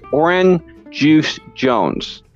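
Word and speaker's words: Oran Juice Jones: Harvey, did I pronounce that right? – Yes, and it's hard to say it wrong. It's Orin Juice and then Jones. You know, you did a good job, right?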